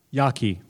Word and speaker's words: Jaki: Of presentation and 20 minutes, followed by 20 minutes of Someone suggested Stanley Jakey's. Did I say that right? Yaki, thank you. J-A-K-I, as a good source for communication and critique of scientific ideas.